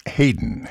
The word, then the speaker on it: Haydn: The story says his mother named him after Joseph Haydn, although for whatever reason, it's pronounced Hayden, Hayden Wood, wrote the variations on a once popular song.